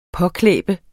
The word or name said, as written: påklæbe